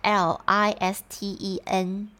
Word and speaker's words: listen: 好，再来另外一个就是 listen to，这个 listen 呢，它怎么拼呢？它其实是 L I S T E N。那这个 t 其实是不发音的，所以我们在念的时候不会念 listen，会念 listen。那个 t 你就把它当做是有那个形，但是没有那个音。